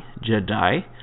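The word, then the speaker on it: Je'daii: But it's the Order of the Jedi, and that is spelled J-E-apostrophe-D-A-I-I, Jedi.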